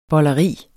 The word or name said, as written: bolleri